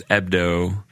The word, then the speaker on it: Hebdo: And Hebdo has a silent H, so it's Ebdo.